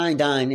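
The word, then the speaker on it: Condign: And he was talking about, you know, his time working on Project Condyne. Is that how you pronounce it? – Yeah, Condyne in UK. Yes.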